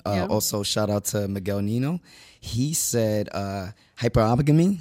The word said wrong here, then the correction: hypergamy: I believe I said that right. – Hypergamy. – Hypergamy. Yep. Excuse me.